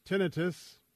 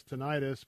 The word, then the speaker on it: tinnitus: I have yet another challenge on my plate, which is for those of you who have had to deal with tinnitus. A lot of people mispronounce it as tinnitus, but it's tinnitus.